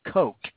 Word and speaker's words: Koch: Maybe if you learned how to read, maybe if you went to school and learned how to goddamn read, K-O-C-H is pronounced Coke. Kind of like that white powder you stuff up your frickin' nose.